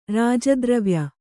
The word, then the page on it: ರಾಜದ್ರವ್ಯ: Kannada